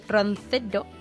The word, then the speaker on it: Roncero: Bueno, pues Paco Roncero se da Roncero. Sí, lo he dicho bien.